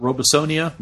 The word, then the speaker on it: Robesonia: And then there's also going to be an event that's sounds like it's more of an off-road technical course called Robesonia. Am I saying that correctly? – Yeah.